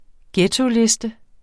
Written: ghettoliste

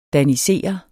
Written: danisere

